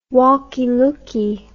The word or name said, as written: walkie-lookie